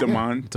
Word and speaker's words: DeMond: And Baby, I'm Back with, it was Damon Wilson's DeMond. – First. – DeMond Wilson. Uh-huh. – Yeah, I never, that's how he pronounced his name? – Yeah. Yeah. – DeMond.